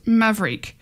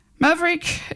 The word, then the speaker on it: Mavreiqe: So now we're looking at our LGBT of the week, which is Maverick. So it's spelt M-A-V-R-E-I-Q-E. So that's why it's a bit funny pronouncing.